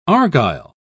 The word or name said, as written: argyll